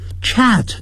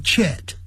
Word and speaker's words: chat: I chat over dinner with my parents， chat 就是聊天，不要念成 chat，要念成 chat， adding I chat over dinner 就是边吃饭边聊天，那个 over 就是越过晚餐聊天，就是介于聊天吃完晚餐这样子，就是边聊边吃。